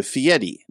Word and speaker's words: Fieri: So in 1995, up till that time, his name was Ferry, F-E-R-R-Y. And when he got married, he changed it to Fieri. Now, it's spelled F-I-E-R-I, but in Italian, the way you pronounce your R's, it sounds like a T or a D.